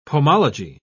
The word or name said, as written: pomology